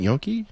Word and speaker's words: gnocchi: See, I'm a chicken and gnocchi girl. – Oh, okay. I do like, how do you say it? Gnocchi? – Gnocchi.